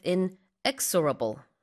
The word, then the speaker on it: inexorable: Same with inexorable, the stress is on the second syllable.